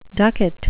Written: ducat

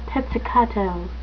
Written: pizzicato

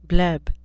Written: bleb

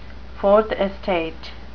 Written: fourth estate

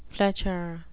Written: fletcher

